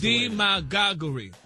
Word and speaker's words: demagoguery: You got D-E-M-A-G-A-H-G-U-H, re. – Well, that's demagoguery. – That's the way the dictionary broke it down. – The pronunciation, like the emphasis is on the D.